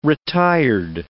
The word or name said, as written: retired